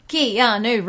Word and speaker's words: Keanu: First of all, three syllables, Wait, four syllables. Keanu Reeves.